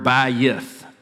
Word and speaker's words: bayith: It's translated into English as house, but it's the Hebrew word bayith, two syllables.